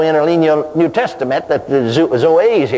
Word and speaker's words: Zoe: It is pronounced Zoe. Now I'm not a Greek scholar, but the professor of Greek in Baylor University, you know, in Waco, Texas, Baptist Seminary, told me that that's the way you pronounce it, is Zoe.